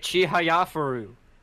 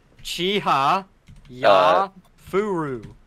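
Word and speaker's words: Chihayafuru: You say it Chihaya Furu. – Oh, Kia. – It's not hard. – Wait, how do you spell it? – Chihaya Furu.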